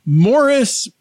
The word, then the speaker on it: Maurice: Morris Gibb, his name is spelled Maurice. – Yes. – But they call him Morris.